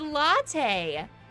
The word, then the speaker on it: latte: What's a late? it's pronounced latte.